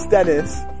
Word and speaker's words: Dennis: My name is Dennis. – Dennis, can you spell that? – Yes, it's D-E-N-N-I-S.